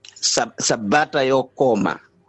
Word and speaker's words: sabata yokoma: So we say sabata yokoma. – Sabata yokoma. – Yes.